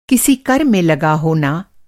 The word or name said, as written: लगाना